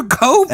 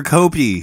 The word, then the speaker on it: Cope: Is it Cope or Copey? Because it's spelled C-O-P. – Yeah, it's spelled cop with an E, right. – But it's Cope.